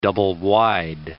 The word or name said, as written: double-wide